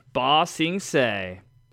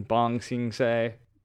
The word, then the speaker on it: Ba Sing Se: Earth Kingdom, Ba Sing Se. They got this for me because I kept saying Bong Sing Se.